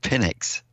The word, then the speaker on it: Penix: It's Penix, I think is how you pronounce it. You got to be careful with that pronunciation.